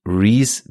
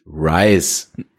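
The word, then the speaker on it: Rhys: Ähm, um eine Nickeligkeit zum Schluss noch mal kurz klarzustellen, der Schauspieler John Reese Davis spricht sich Reese Davis aus, nicht Rice. Reese ist walisisch.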